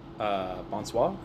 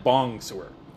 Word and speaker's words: bonsoir: I can't believe I pronounced that correctly. But you know what? Well, if I mispronounced it, I wouldn't have said bong-sewer, okay? Would not have pronounced it that horribly. – No, not bong-sewer.